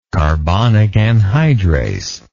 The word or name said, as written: carbonic anhydrase